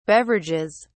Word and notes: beverages: British English

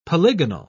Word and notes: polygonal: British English

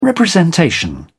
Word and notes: representation: British English

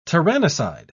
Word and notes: tyrannicide: British English

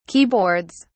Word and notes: keyboards: British English

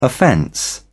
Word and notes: offence: British English

